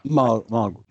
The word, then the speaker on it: Mulgrew: And her name is Kate Mulgrew. Am I saying that right? – Mulgrew.